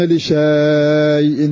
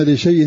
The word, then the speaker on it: لشيء: ولا تقولن لشيء، مكتوب هكذا مصحف هكذا بالألف ألف. ولا تقولن لشيء إني فعل ذلك غدا، تجدها في المصحف عندك. ولا تقولن لشيء، ومع هذا فإنا نحذف هذه الألف لا ننطقها.